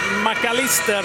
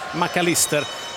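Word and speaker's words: Mac Allister: till exempel McAllister. De säger McAllister i Argentina, de säger inte McAllister, även om man har ett engelskklingande namn.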